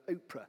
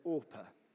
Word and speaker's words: Orpah: You may have heard the story, but so many people mispronounced her name that eventually her parents gave up and decided to call her Oprah instead of Orpah.